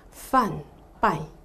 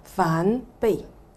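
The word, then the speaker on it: 梵唄: 你去查詞典咧，啊個讀音應該係梵拜，梵拜嚇。咁、啊、但係我哋跟住嚇啲老法師佢哋一路咁樣傳落嚟嗰個讀音發音咧係叫梵悲，啊梵悲嚇。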